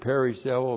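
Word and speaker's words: perisseuo: Periseuo, P-E-R-I-S-S-E-U, long O. Periseuo.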